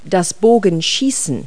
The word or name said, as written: archery